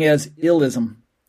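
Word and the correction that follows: illeism: But it's actually illism. Illyism. So I-L-L is ill, and then the E gets pronounced E, and then the second I, I. Illyism.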